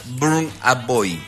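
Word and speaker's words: Burna Boy: Y justo escogieron un cantante que se pronuncia Burn a Boy.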